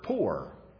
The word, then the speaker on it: pur: The Greek word is the word pur. You could actually spell it and transliterate it, P-U-R, or as to understand it, P-O-O-R, but it's simply a word that means to burn or to ignite.